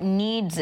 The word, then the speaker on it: NEADS: So tell us, first of all, what NEEDS is, and that's spelled N-E-A-D-S, right? – Yes, that's correct.